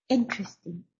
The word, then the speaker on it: interesting: Pongan bien el acento en la primera sílaba. Interesting, interesante.